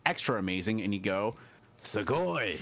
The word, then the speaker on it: sugoi: Sugoi. – And what does sugoi mean? – It's su-go-i. Sugoi. – Great pronunciation.